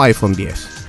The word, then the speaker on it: iPhone X: De hecho, no se dice iPhone X, es iPhone X o iPhone 10.